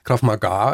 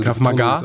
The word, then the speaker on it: Krav Maga: Das andere ist, was ich noch nie gehört habe, Krav Maga. Ja. Spricht man das so aus? – Krav Maga. Also Krav Maga.